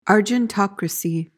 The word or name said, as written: argentocracy